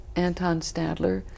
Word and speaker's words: Anton Stadler: Mozart's clarinetist was Anton Stadler. – Right. – Did I say that right? – Yes, yes, you did.